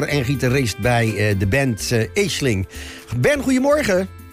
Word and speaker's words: Aisling: Aisling. Aisling. Ja, A-I-S en dan Ling. – Aisling. – Ja, dat is een Gaelic woord. En dat wordt altijd net even anders uitgesproken dan hoe je het schrijft.